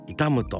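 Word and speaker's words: 悼む: この痛む人の痛むというのは亡くなった人の冥福を祈ること、追悼の塔の字を書いて痛むと読みますね。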